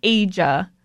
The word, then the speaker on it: Aja: It's pronounced like Asia. It's like Aja, but it's hard for me to say.